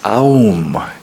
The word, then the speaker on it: Om: The Om is also called Pranava, and it means the sound from which everything comes, Pranava. Sometimes it is written as Aum, A-U-M. At other times, Om.